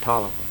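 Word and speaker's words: Taliaferro: Talifera, they called it, but we called it Tolliver.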